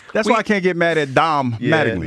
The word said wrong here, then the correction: Don: No, again. It's Don, and you also know his name. You just mispronounced.